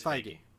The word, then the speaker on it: Feige: I believe it's Feige. – It's Feige? Okay, let's go with Feige.